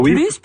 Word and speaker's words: plus: Il y a une petite différence, par contre, dans sa prononciation. – Oui. – Lorsqu'on veut dire qu'il y a absence de quelque chose, on ne prononcera pas le S. Tandis que on va prononcer parfois souvent le S lorsque on veut dire davantage, mais dans certains cas, là, lorsque c'est en début de phrase, il est plus grand, par exemple, on dira pas il est plus grand, on va dire il est plus grand.